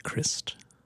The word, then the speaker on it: Christ: The first act is Brody Christ. Rhymes with wrist, not...